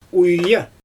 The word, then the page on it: uija: Xyzyl